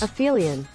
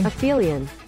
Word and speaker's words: aphelion: Aphelion. – Aphelion Day? – Aphelion.